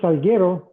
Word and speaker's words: Salguero: So, Francisco Salguero again. Um, so last name is Salguero, is a Spanish pronunciation.